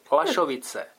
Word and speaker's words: Lašovice: PS, Lašovice, rovná se LA. Vyslovuješ to naprosto správně. Starousledlíci i přátelé to také tak vyslovují.